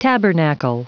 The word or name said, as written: tabernacle